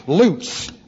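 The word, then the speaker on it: lutz (לוץ): It looks like this, and it is pronounced lutz, L-U-T-Z.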